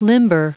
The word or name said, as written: limber